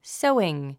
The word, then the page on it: sewing: sóuiŋ; ソウイン